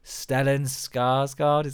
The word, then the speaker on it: Stellan Skarsgård: And then he is led by the Baron, and the Baron is played amazingly by, oh, I always get his name wrong, Stellan Skarsgård.